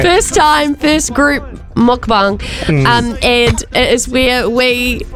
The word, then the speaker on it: mukbang: Mukbang. – Mukbang.